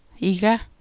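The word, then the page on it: iga: Greenlandic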